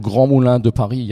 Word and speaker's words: Grands Moulins de Paris: Ja, also Grand Moulin de Paris, ja, das ist die große Pariser Mühle äh, mit Sitz okay.